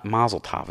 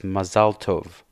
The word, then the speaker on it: Mazel Tov: So they go Mazel Tov. It's not Mazel Tov. It's Mazel Tov. – Mm. – So that's how it's supposed to be pronounced.